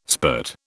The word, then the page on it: spurt: British English